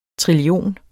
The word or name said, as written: trillion